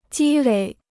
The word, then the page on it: 积累: jī lěi